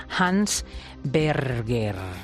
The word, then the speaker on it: Hans Berger: Es el creador del encefalograma, de la máquina que registra los movimientos cerebrales y que se llamaba, este señor, Hans Berger. Creo que lo he dicho bien. Muy buenos días. – Perfecto.